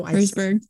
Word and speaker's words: Herzberg: I have brought back the Brittany Herzberg. No, Herzberg. – I said it wrong, didn't I? – Well, let's just roll with it.